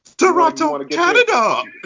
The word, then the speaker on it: Toronto: Yes, he's from Toronto. – Whoa. What's up? That's not how you say it. – Oh, here you go. Right. – Toronto, Canada.